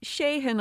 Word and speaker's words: Sheahan: Well, it's Sheahan, I suppose, because it's S-H-E-A-H-A-N.